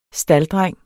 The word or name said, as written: STALDDRENG